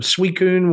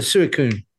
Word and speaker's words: Suicune: That I knew how to pronounce it? – Okay. Justin wouldn't have gotten it. – Yeah, he wouldn't. He would have called it a Suicune.